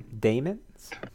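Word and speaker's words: dæmons: It's demons. – It is demons. – Mm-hmm. – Okay. – I double checked the pronunciation online today. It's technically supposed to be demons.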